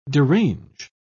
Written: derange

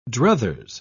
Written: druthers

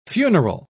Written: funeral

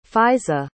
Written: faizah

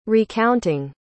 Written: recounting

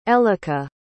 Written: elica

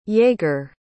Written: jaegar

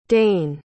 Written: dane

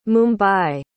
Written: mumbai

